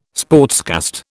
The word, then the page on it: sportscast: British English